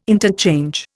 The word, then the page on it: interchange: Indian